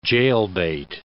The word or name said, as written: jailbait